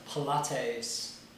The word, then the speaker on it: Pilates: So, if anyone likes school us in the art of Pilates...